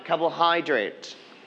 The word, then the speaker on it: Carbohydrate: Carbohydrate. – Carbohydrate. – Carbohydrate. Very good. We are going to stress on the third syllable, hydrate.